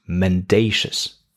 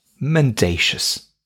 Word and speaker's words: mendacious: It contains three syllables. Men, like the plural form of a man. Day, like the unit of time containing 24 hours. And the last syllable, shus, which contains this strange schwa sound, shus. So, men, day, shus, mendacious.